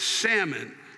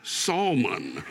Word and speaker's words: salmon: The L in salmon is silent. It's salmon, not salmon, you motherfuckers.